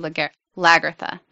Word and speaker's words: Lagertha: So meanwhile, with our favorite son of Ragnar, Bjorn delivers the news of the invasion of Kattegat by Jarlborg to Lagertha. That's how they say it in the show.